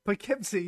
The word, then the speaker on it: Poughkeepsie: So it's in Poughkeepsie, is that how you say it? – Poughkeepsie, yeah, it's about Poughkeepsie.